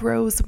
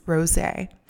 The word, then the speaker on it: rosé: And after a lot of embarrassing moments, like the time I shouted out, I love rose wine. Yes, I pronounced it like the flower instead of the correct way, which is rosé.